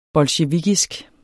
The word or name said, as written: bolsjevikisk